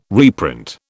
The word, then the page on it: reprint: British English